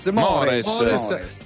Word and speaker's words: Mores: Italiani, tra poco ci collegheremo con la Sardegna dove c'è questo nostro amico Sandro Falzoi da Mores, come si pronuncia? – Mores.